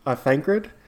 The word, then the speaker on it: Thancred: Like one thing that made me laugh, I'm going to probably say his name wrong, Thancred.